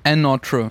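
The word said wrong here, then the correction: Anotr: featuring 54 Ultra. – Ich glaube, Another wird ausgesprochen.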